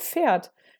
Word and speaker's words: Pferd: Für mich Also da war ich richtig sauer, weil ich mir so dachte, warum schreibt man das mit PF, aber man sagt Pferd. Aber eigentlich sagt man natürlich auch Pferd. Aber Ja. – Ne, das sagt ja irgendwie keiner so richtig. Also beziehungsweise das P geht so ein bisschen unter.